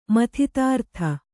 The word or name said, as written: ಮಥಿತಾರ್ಥ